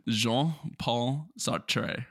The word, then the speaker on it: Jean-Paul Sartre: Let's hear that again. – Jean-Paul Sartre, right? – Magnifique. I would have murdered the French pronunciation on that.